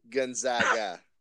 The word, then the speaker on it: Gonzaga: Well, I'll do the first favor for you here, because the rest of the nation always has a hard time pronouncing it. It's actually Gonzaga.